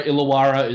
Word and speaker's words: Illawarra: Just because I know that you know the team well, you follow the Illawarra Hawks, and I apologize if I mispronounced it. No, Illawarra is correct.